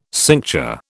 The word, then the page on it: cincture: British English